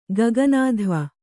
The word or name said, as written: ಗಗನಾಧ್ವ